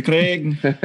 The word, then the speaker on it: Craig: Yep, that was my The Elijah my Clegg? – Craig. – Is that an off-brand? – I got a little Irish on me. It's a Craig. – The Clegg. – The Craig. You guys roll your R's.